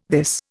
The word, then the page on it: this: Indian